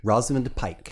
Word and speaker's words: Rosamund Pike: Rosamund Pike.